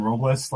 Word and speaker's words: Robles: And he overpronounces names like Lois Robles. Like he rolls the R in Robles like it's Spanish. It's not Spanish. He's from fucking Arizona.